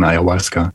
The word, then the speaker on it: Ayahuasca: Was ist das Ayahuasca und habe ich es richtig ausgesprochen? – Ja, hast richtig ausgesprochen, Ayahuasca.